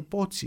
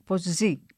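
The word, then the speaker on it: Pozzi: Λοιπόν αυτός ο Πότσι είναι πολύ ενδιαφέρουσα μορφή ε, κατάγεται από μια οικογένεια Ιταλών που μετήκησαν στην Γαλλία ε, και κράτησε το όνομά του με την Ιταλική του προφορά, ενώ ο πατέρας του είχε κάνει ποζή, ποζί, ποζί, ποτσί, κάπως έτσι.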